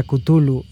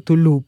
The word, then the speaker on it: Cthulhu: La pronunciación sugerida por Lovecraft, sí lo sabemos, era Cthulhu. Y nosotros siempre le hemos dicho Tulu, pero bien se ha enfatizado muchas veces que nadie está mal, como le quieran decir Chulu, Kulu, Tulu, Cthulhu. En realidad no hay una pronunciación correcta, como el mismo Lovecraft lo dijo.